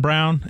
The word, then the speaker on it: Braun: Why is it pronounced Christian Brown and it's spelled Braun? – I don't know.